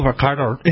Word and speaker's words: Varadkar: It's Leo Varadkar.